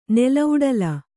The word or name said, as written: ನೆಲೌಡಲ